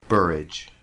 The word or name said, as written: Borage